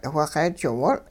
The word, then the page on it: whixeejiwol: hupa